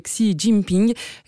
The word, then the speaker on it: Xi Jinping: L'occasion d'une nouvelle démonstration de puissance pour l'actuel président, c'est Xi Jinping, si je le prononce bien.